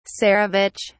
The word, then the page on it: tsarevich: British English